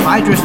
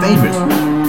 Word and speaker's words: Phaedrus: I have been corrected to say that it is actually pronounced Phaedrus instead of Phaedrus.